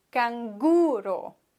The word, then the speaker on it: Canguro: Und da musst du aufpassen, dass du wirklich die vorletzte Silbe betonst, denn im Deutschen betonen wir die letzte Silbe. Wir sagen ja Känguru, aber im Spanischen Canguro.